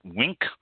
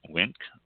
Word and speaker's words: Winc: It says that because the world being shut down, the direct-to-consumer wine club Wink, I believe it's pronounced that way, W-I-N-C, Wink, I think it is, saw an unprecedented 578% increase in new member signups week over week, with 20,000 new members since the start of the pandemic.